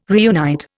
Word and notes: reunite: Indian

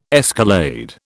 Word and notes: escalade: British English

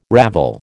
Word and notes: ravel: American English

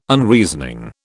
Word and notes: Unreasoning: British English

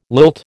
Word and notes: lilt: American English

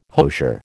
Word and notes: hosier: American English

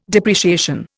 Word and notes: depreciation: Indian

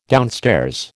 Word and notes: downstairs: American English

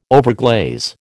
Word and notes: overglaze: American English

